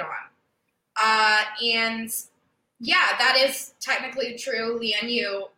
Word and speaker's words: Lian Yu: They call it Leon Yu. Micah, you're not gonna believe this. That's not the correct way to pronounce it. – Oh my gosh. – It's Lian Yu.